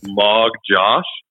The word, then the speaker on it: Magdziarz: I was told that it was Magdziarz. – Magdziarz. – But then I like that. – Yeah, like M-O-G.